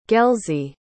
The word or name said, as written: gelsey